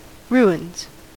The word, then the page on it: ruins: American English